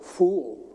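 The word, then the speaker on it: fool (فول): It's this brown bean in a soupy kind of stuff, and it's called, there's two syllables. Listen carefully. It's called fool.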